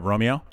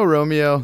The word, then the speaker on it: Romeo: It's just straight up Romeo. – I go Romeo. That's what most of my family does, but it kind of depends if you're a telemarketer or working a grocery store or just where you are, how you want to pronounce it. I, I, I hear a lot of actual Italians say Romeo,